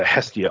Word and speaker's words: Hestia: and I'm going to probably say this wrong, Hesita. Is that how you say it? – Oh, the Hestia.